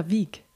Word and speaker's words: Vigh: Welcome to my podcast, Melinda Vigh. Vigh was right the yeah, right pronunciation. It was the right one.